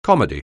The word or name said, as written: comedy